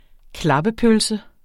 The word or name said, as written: klappepølse